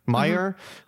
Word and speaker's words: Meijer: Yeah, and they've only been spotted at is it Meijer? Meijer. How do you pronounce it? Meijer, mm-hmm.